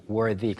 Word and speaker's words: worthy: 훌륭한 또는 자격이 있는을 가리키는 worthy, worthy. 그냥 y가 빠진 형태는 worth th 발음인데 y가 붙으면서 the 발음이 되어서 worthy가 되고요.